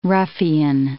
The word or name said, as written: ruffian